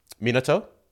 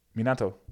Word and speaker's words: Minato: Minato? – No. – What? I said it wrong? – Minato.